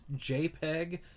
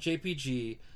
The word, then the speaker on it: JPEG: And by the way, it's JPEG, not JPG. JPEG is the full name of the file format. When you see a JPG file, that is JPEG. And that is how you pronounce it.